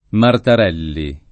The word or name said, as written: Martarelli